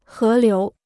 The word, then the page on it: 河流: hé liú